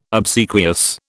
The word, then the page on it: obsequious: British English